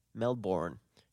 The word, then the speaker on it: Melbourne: It's Melbourne.